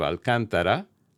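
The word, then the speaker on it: Alcântara: in the city of Alcantara, located at Brazil's northern Atlantic coast. Those two paraproxetonic names have the emphasis on the third-to-last syllable, in other words, the second one.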